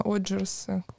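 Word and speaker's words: Odgers: Олджерс, uh, Оджерс.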